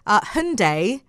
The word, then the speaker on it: Hyundai: Hyundai, to rhyme with Sunday, so no, Hyundai. Hyundai.